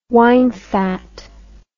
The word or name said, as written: winefat